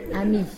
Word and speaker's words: ami: Ami.